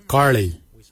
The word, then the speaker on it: giilyi: Darb is spelled D-H-A-A-B. It is a traditional knife, a darb. For boomerang, we say karli. Karli is the traditional name for boomerang. Karli is spelled G-I-I-L-Y-I, karli. For the traditional stone axe, we say koch, koch. Koch is spelled G-O-O-D-J, koch.